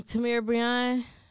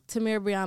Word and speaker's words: Tamira Breon: Just I'm um, Tamira Breon. Remember my name. Tap in with me if you don't already know me. Um, Tamira Breon on all platforms: Instagram, Twitter. Clubhouse, Snapchat, everything's Tamira Breon.